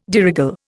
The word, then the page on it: de règle: Indian